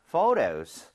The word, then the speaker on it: photo: 그리고 참고로 ph는 f와 성질이 비슷하기 때문에 발음할 때 포토가 아니고요. Photo 이렇게 부드럽게 해주셔야 돼요.